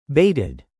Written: baited

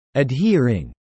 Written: adhering

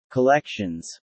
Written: collections

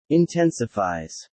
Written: intensifies

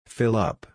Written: fill up

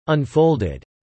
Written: unfolded

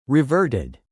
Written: reverted

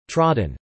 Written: trodden

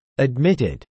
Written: admitted

